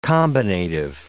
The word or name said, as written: combinative